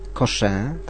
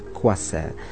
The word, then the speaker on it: Coixet: październiku w kinie Millennium film Andrzeja Seweryna, Kto nigdy nie żył, Życie ukryte w słowach, piękny obraz hiszpańskiej reżyserki Isabel Coixet, tak się wymawia podobno to nazwisko, chociaż na dobrą sprawę nikt nie wie, ja bym powiedział Coixet, ale to baskijka, więc kto mówi po baskijsku, ten Boże mnie nauczy, jak się wypowiada to nazwisko.